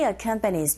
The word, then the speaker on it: accompanies: While I look around the island, he accompanies me. He, 붙여서 발음하시고요. Come, 이 부분에 강세 있어서 he accompanies me.